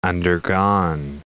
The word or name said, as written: undergone